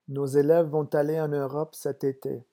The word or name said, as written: nos élèves vont